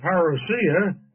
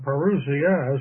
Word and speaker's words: parousia: The coming of verse 1 is the parousia, or parousia, as some pronounce the word.